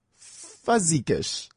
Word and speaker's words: Fuzigish: F-U-Z-I-G-I-S-H. Fuzzy Gush.